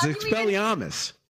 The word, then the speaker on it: Expelliarmus: You didn't even say it right. – You did Expelliarmus, but you know. – Armus? – Yes. – No, it's Amus. It's Expelliarmus.